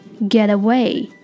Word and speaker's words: get away: 那除了上面说到的这些例句之外，这个短语表达还有个发音的特点，就是可以连读 get away，可以变成 get away get away。这里面的 t 因为在两个元音之间，所以可以浊化成类似于 d 的音，变成 get get get away。